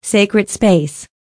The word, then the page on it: sacred space: American English